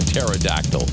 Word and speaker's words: pterodactyl: Why can't you hear a pterodactyl going to the bathroom? You know those pterodactyls? The big, you know, the... Why can't you hear a pterodactyl going to the bathroom? Because the P is silent. Pterodactyl.